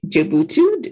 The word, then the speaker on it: Jebutu: This week, we have with us Temi Jebutu.